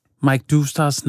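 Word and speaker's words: Mike Doustdar: Så nu, her i sorte tal, der vil jeg simpelthen for hele Erhvervs Danmark og alle andre, der måtte lytte til det her, simpelthen komme med den rigtige måde at udtale det på. – Ja. – Og det er ikke mig, der har fundet på det. Det er altså manden selv, Mike Doustar.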